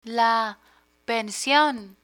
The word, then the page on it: pension: Spanish